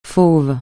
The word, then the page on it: fauve: francais